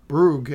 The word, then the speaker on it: brogue: Amalia starts off by imitating Penance's Bruges accent. Bruges is the right word, right? I'm pronouncing that right. That's a Irish, Scottish Bruges. That's what Brogue. – She's got. Brogue. There you go.